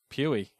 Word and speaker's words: Puey: It's P-U-E-Y. How would you pronounce that? – P-U-E-Y. – Pewee? – Pewee, yeah. – Or Pooey? – Let's go with Pewee.